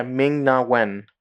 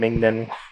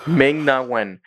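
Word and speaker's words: Ming-Na Wen: Yeah, Ming Na Wen. I, I fucked that all up. I said Ming Then. Anyways, Ming Na Wen.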